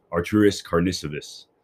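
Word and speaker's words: Artūras Karnišovas: It's Arturis Karnisovas.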